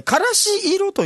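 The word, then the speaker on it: 芥子色: からし色と読むそうですね、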